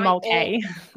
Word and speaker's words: Mulcahy: Tanja Mulcahy.